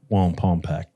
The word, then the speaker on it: Wallenpaupack: Yeah, definitely going to get into Kunda to grab more Wallenpaupack.